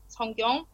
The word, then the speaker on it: Sungkyong: yeah, so what's happened is that um, I was born with my Korean name, which is Sungkyong, and the Kyung sound is really hard to pronounce in English.